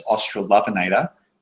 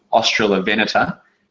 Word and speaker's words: Australovenator: The first one is, you might read it, and I did it first as Australopinator, but the emphasis is on the on the fourth syllable there, Australovenator.